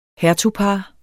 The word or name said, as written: hertugpar